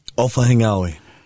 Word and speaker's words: Ofahengaue: How do you say your last name? Ofahengaue. That's how you say it.